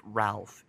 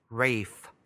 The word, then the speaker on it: Ralph: His name is not pronounced Ralph. It's Ralph Fiennes.